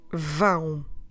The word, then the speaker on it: vão: Ão. And along with the consonant, we get vão.